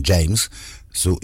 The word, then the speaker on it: James Zhu: James Zhu, no sé cómo se dice James Zhu, es James como un James, Zhu, pero Zhu como el zoo, ¿no?